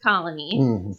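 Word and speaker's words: Koloni: It's K-O-L-O-N-I, but it's pronounced Colony.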